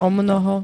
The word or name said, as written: omnoho